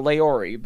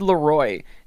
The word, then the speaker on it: LAROI: I always thought Laori, but yeah, I'm horrible at pronouncing words. Kid LAROI.